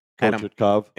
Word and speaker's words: Kochetkov: I mean, even when Freddie's hurt, they have Antti Raanta, then they have Kognetsov. How do you pronounce it? – Kochetkov. – There you go.